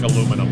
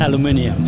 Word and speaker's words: aluminum: And we're going to have conversations about how he pronounces words that I don't pronounce like he does, like aluminum. – Aluminum. – How do you do it? – Aluminium. – See? No, right off the bat, we disagree.